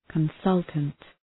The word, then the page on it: consultant: kən’sʌltənt